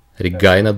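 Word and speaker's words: Regina: Это Регина, или кажется правильное произношение на английском, это Регайна Дуган.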